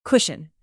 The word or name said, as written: cushion